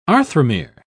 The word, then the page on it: arthromere: British English